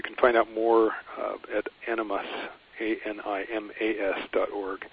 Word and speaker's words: animas: I don't pronounce it well myself, but it's animas. A-N-I-M-A-S. It's not the Jungian word animus, which means essentially the masculine inside of a woman. It's souls. Yeah, animas.